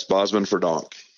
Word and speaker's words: Bosmans-Verdonk: Tell me on the pronunciation, Benjamin Bosman. – Yes. Bosman Verdonk. – Bosman Verdonk. Okay. Bosman Verdonk.